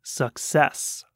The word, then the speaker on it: success: So the first C is a hard C. It makes the k, k sound, suck. And the second C is a soft C. It makes more of an S sound, success, success.